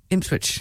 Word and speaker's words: Ipswich: Just say Ipswich again. – Ipswich. – There you go, lovely. – Correct. – Because that's place. How it's pronounced.